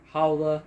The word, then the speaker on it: Haula: I hear it's Hulia, Haula. I hear a lot of different stuff. It's Haula.